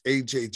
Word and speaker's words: Ajay: Yeah, so my name is AJ Jackson. I spell it capital A-J-A-Y.